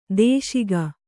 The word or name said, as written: ದೂಷಿಕೆ